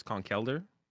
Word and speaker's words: Conkeldurr: It's not Conkeldurr, it's Conkelder, Bro, I don't know.